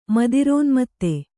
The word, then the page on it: ಮದಿರೋನ್ಮತ್ತೆ: Kannada